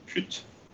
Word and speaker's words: pyt: We've got another word from Denmark or from Scandinavia, which is making headlines, which is pyt. It's P-Y-T, pyt.